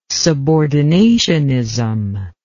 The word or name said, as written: subordinationism